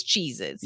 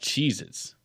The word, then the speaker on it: Cheez-Its: They wrote Cheez-It crackers, but we all know it's Cheez-Its. – Yeah, that's pronounced Cheez-Its.